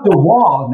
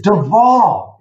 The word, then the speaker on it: de Waal: It's not Duval, Nathan. It's Duval. It's Dutch. So the W is pronounced as a V.